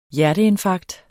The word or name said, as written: hjerteinfarkt